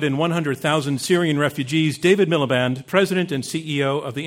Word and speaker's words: Miliband: I pronounced his name wrong. What did I say? What did I say? – Miliband. – I put the R in?